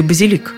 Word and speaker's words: базилик: Базилик или базилик? Не возмущайтесь, некоторые старые словари действительно приводили ударение «базилик», Проблема та же. Слово заимствовали из французского, где ударение на последнем слоге. Во французском – базилик – с ударением на последнем слоге. Но в латинском-то было «базиликус» с ударением на втором. Сейчас только «базилик».